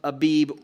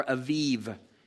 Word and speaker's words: Aviv: No leavened bread shall be eaten, and on this day you shall go out in the month of Abib," or Aviv is how that's pronounced. The Hebrew word is Aviv.